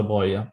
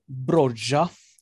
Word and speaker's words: Broja: Eh, Armando Bro Broja, tydligen. Eh, från en absolut inte eh, tillitsfull källa eh, som man tror uttalar Broja, bara för att eh, mm.